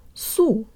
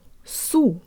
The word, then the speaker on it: су: The S is a preposition. We tack it right on to the next word, so the first syllable becomes су. Let's say it with good Russian default mouth position. Jaw slightly forward, tip of the tongue behind the bottom teeth, lips rounded and protruded way more than you would in English. Су. Су. The O in the next syllable sounds like an ah-flavored schwa.